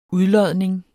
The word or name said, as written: udlodning